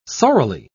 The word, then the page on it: sorrily: British English